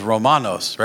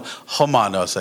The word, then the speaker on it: Romanos: In my ring, it says Romanos, right? No, Homanos, I guess, right? R's are H sound.